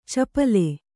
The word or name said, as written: ಚಪಲೆ